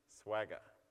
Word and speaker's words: Swagger: Swagger, okay? If you can't remember it, it rhymes with Mick Jagger.